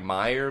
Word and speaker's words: Mayer: TikTok CEO Kevin Mayer, I believe Mayer, M-A-Y-E-R. Are you familiar with him? – Oh, no, no. – That's. – I thought you were asking how to pronounce it. – It was like Meyer, maybe, or something. – But John Mayer spells his that way. – Yeah. Okay. We'll go with it.